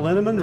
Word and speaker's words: Linneman: The equally mysterious Tracy Z, Aviva Lael, Alicia Lemus, Rebecca Hughes, Val Linneman, Rhymes with Cinnamon,